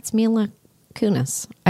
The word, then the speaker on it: Mila Kunis: Oh, that's Mila Kunis.